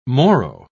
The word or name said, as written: morrow